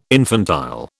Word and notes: infantile: British English

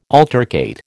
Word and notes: altercate: American English

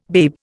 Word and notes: babe: Indian

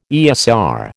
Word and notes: ESR: American English

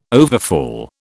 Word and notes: overfall: British English

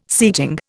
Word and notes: seating: Indian

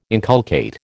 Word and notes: inculcate: American English

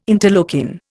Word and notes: interleukin: Indian

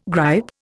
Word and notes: gripe: Indian

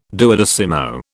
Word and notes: duodecimo: British English

